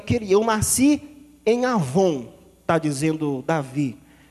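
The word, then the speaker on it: avon (עָוֹן): Mas a palavra pecado aqui, há um termo hebraico que nós o pronunciamos como avon. Não tem nada a ver com o produto que você come. Avon é a palavra.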